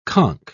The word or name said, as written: conch